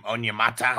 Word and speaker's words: Onyemata: Onyemata.